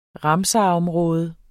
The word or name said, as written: ramsarområde